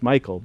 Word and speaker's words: Michal: And so I think it's important to understand that it's M-I-C-H-A-L. So some will say Michal, to try to differentiate. But what I've been told by the scholars, the actual pronunciation is Michael, but it's a female spelling and version of the name.